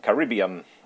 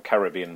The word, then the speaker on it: Caribbean: It's in uh, in Caribbean or Caribbean blue, depending on your pronunciation, which is the uh, the best colour to have in my opinion.